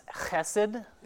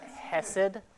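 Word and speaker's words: chesed (חסד): It's pronounced chesed, not hesed.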